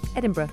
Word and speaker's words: Edinburgh: Am I saying it right? Edinburgh. – Edinburgh. – Correct.